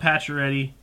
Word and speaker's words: Pacioretty: Still love Pacioretty. Can't be too upset. But wait. Did I say that right? Yeah. – Yeah, you got it.